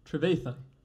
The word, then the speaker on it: Trevathan: Danny Trewanthan? Trevathan. Trevathan, ja.